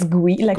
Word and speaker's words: Gouy: Yeah, it's Gouy, but with a G in front of it.